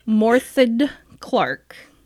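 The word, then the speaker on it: Morfydd Clark: Morthed Clark. – I think that's as close as anyone can get. – Yeah. I think I put a little too much Ed on the end, but I'm trying, and I'll get better at it.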